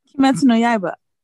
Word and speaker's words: Kimetsu no Yaiba: Kimetsu no Yaiba. How do you pronounce it? – Kimetsu no Yaiba. – Okay. I did pretty good.